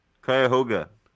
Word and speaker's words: Cuyahoga: Coincidentally, the Cuyahoga River, did I pronounce that right? – Cuyahoga